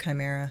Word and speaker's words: chimera: Which also can be pronounced Chimera or Chimera. There's lots of different pronunciations, but I'm just going to stick with Chimera. – Okay. – Because that's how I've always heard it pronounced.